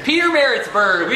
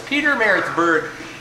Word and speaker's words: Pietermaritzburg: Pietermaritzburg, South Africa. Because Pietermaritzburg. – We have got the official statement from the official guy on the couch next to us. Pietermaritzburg.